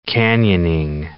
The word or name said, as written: canyoning